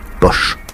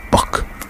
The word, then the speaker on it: Bosch: Car Jérôme Bosch peut se prononcer Bosch ou Bock.